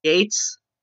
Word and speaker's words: Gaetz: Now, I've covered Matt Gaetz, and I've been told, by the way, his name is actually pronounced Gates.